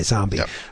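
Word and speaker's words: Xombi: So I never bought Zombie with an X. X-O-M-B-I, Zombie, by John Rosum.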